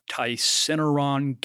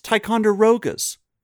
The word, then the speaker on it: Ticonderoga: It's Ticonderoga's. – Oh, Ticonderoga's. – Wow. – John Kimble. – That was so awesome. You really thought it was Ticinderaga.